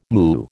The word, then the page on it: moo: American English